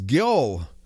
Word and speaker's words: Gjol: A Nordic word for the winter solstice was Gjol, the G being pronounced like our Y, thus Yule or Yule.